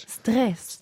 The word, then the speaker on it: Stress: Stress, oder wie sagt man Stress oder Stress? – Stress. – Ah, danke, eben. – Diese Französisch ausgesprochen.